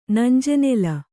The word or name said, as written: ನಂಜೆನೆಲ